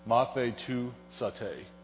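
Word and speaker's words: Mathe tu sate (μαθητεύσατε): The word here, it's five syllables. It's mathe tu sate. Say that with me.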